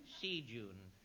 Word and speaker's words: Seagoon: P-U-G-H. – Yes. – There. Neddy Pug Seagoon. Wasn't it? – Yes, Seagoon. S-E-A-G-O-O-N. – Could you spell it? – Certainly. S-E-A-G-O-O-N.